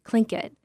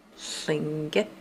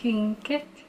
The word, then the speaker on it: Tlingit: At KTOO, we usually pronounce it Tlingit, but in a story that's all about pronunciation, I gave the real way a try.